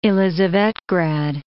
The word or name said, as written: Elisavetgrad